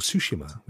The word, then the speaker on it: Tsushima: I've just picked up even Ghost of Tsushima, or Tsushima, which is actually how it's supposed to be pronounced.